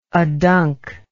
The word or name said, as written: adunc